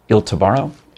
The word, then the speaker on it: Il Tabarro: Il Tabarro.